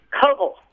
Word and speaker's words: Koval: We're talking about George Koval. Is that pronouncing it correctly? – It's actually Koval. – Koval. – Koval. I was corrected by my Russian translator.